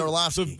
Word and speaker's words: Orlovsky: Think of like O-R-L-O-F-F. – You're saying like it has an F in it, Orlovsky. – Yeah, that's how it's pronounced. – Okay, see, I didn't know. – Dan Orlovsky.